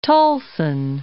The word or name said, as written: Tulsan